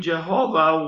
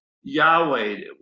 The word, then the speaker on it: Yahweh: And he appeared unto Abraham, unto Isaac, and unto Jacob by the name of God Almighty, but by my name Jehovah was I not known to them. Now, we pronounce it Jehovah, but I think it's pronounced Yahweh, YHWH is what this name really is.